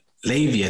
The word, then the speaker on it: Levi: Now, Levy is spelled L-E-V-I, like Levi, like the jeans. My parents kind of they called me Levy, but they spelt my name Levi, just to confuse everyone. No, it's really the Jewish, Ashkenazic Jewish pronunciation of Levi is Levy.